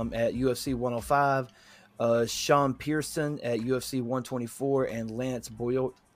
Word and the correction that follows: Benoist: I could never pronounce that last name. Lance Benoist.